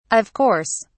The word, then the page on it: of course: British English